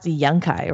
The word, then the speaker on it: Yunkai: So this is pronounced Yunkai, right? – Yeah.